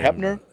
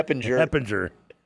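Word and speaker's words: Hoeppner: Like, he took over for Heppinger, if I'm pronouncing his name right, I hope I am. I'm not shocked about him. – Heppner? – Heppner, yeah.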